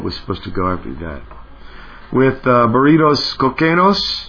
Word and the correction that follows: Cocaínos: Cocaínos. – Oh, yeah, Cocaínos. Sorry. Bad pronunciation.